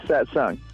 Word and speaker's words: Satsang: Satsang.